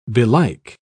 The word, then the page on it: belike: British English